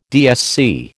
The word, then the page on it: DSC: American English